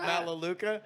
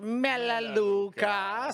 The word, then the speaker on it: Melaleuca: And I'm like, so you're talking to me about Malaluca? – Like, first of all, we pronounce it Melaleuca.